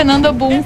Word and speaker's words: Nando Boom: E na sequência, Nando Boom com Dembow.